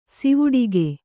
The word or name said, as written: ಸಿವುಡಿಗೆ